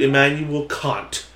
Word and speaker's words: Immanuel Kant: We're going to do Immanuel Kant.